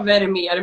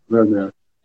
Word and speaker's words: Vermeer: Ja, det är många som tror det och det är många som tror att vet uttalas med F. Men det ska ju vara Vermeer med V. – Vermeer.